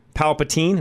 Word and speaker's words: Palpatine: He says, can't call in, best villains, Emperor Palpatine, am I saying that right? – Yes.